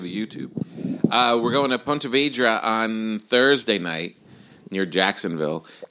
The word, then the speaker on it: Ponte Vedra: Yeah, Ponte Vedra.